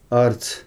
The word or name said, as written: arc